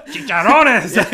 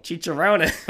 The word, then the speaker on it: chicharrones: Chicharrones. – You didn't roll your R. – I can't. – Chicharron. – Yeah. – Show me your tongue roll. – Chicharrones.